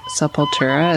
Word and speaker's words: Sepultura: So you sent me a link to them covering Sepultura. Is that how you say it? – Yeah.